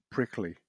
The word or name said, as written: prickly